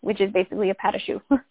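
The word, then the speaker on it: chou: And it's spelled funny, so it's C-H-O-U-S. – Yeah. Let's see. Shoe.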